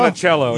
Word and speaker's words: Monticello: We've been mispronouncing that name the entire episode, Monticello? By the way. – Was it Monticello? – Monticello, yeah. Monticello. I'm not Italian, dude.